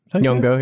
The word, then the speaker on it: Nyong'o: How do you say her name? Nyong'o? – Nyong'o, yeah.